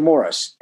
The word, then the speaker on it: Morris: You know, dear Mr. Morris, M O R R I S, spell my name wrong.